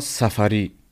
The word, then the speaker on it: Safary: Du hast mir gerade schon gesagt, wie es auf Persisch ausgesprochen wird. Vielleicht kannst du noch einmal assistieren. – Äh, Puria Safari.